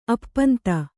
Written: ಅಪ್ಪಂತೆ

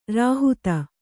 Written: ರೋಹಿತ

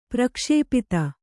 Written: ಪ್ರಕ್ಷಿಪ್ತ

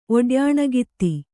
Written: ಒಡ್ಯಾಣಗಿತ್ತಿ

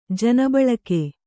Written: ಜನಬಳಕೆ

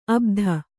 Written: ಅಬುದಿ

